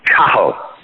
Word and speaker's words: Cathal: and the way it's properly pronounced is Cahill. So you take the T out, and it's just like saying cat without the T, cat, and then a hill.